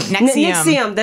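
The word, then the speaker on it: NXIVM: Nixium. This is how you say it. I say the V.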